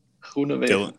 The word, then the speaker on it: Groenewegen: So, how do you pronounce it? – Groenewegen.